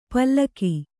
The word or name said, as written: ಪಲಕು